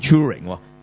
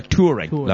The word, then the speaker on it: Turing: 咁所以咧，其实呢啲系唔同嘅、即系其实系冇所谓嘅，即系英文嚟讲有唔同发音，所以我开头哇一额汗，我以为我几十年嚟读错添吓，即系 Alan Turing、其實佢係 Turing，兩個讀法都啱咁樣。